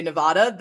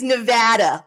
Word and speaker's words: Nevada: People will know that you're not from here if you say Nevada. That's how East Coasters say it, Nevada. It is Nevada.